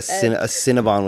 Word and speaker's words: Cinnabon: Well, it's spelled B-O-N. – A Cinnabon. – Well, bun is B-U-N. Don't test me. – Yeah, it is a Cinnabon, but no one says it like that.